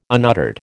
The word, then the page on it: unuttered: American English